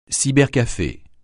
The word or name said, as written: cybercafé